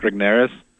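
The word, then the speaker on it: Regnerus: Like early on in the book, you talk about the research, and I'm probably going to mispronounce his name, but is it Mark Rigneris? – No, that's right.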